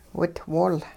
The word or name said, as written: witwol